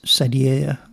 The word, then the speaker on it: Sadier: Actually, there's no L in it. It's Sadier, I think, or S-A-D-I-E-R.